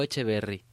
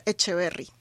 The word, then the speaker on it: Echeverry: ¿Echeverry o Echeverry? – Hola, buenas tardes. Muchas gracias. Mi apellido se pronuncia Echeverry. No tiene tilde, Echeverry.